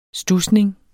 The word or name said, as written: studsning